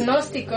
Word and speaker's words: agnósticos: Tiene otras eh, posturas, eh, fundamentos que comparte con el pues se podría decir que con el angostismo o con los angósticos. Pues pero es... Agnósticos. Agnósticos. Sí. dijiste angósticos. – Dije angósticos. Agnósticos, perdón.